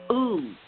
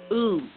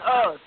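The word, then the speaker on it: Uz: Ooth. U-Z. Ooth. – Oh, I'm pronouncing it different than you. Okay, I'm saying us.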